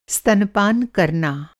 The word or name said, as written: स्तनपान